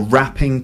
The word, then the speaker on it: wrapping: W would be the wise men, or wrapping paper. Wrapping, that's WRAPPING, to wrap. It's a silent W, isn't it?